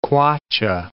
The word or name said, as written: kwacha